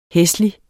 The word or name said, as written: hæslig